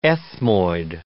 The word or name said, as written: ethmoid